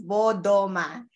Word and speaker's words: Bodoma: Literally, Bodoma. Phonetically, it's Bodoma.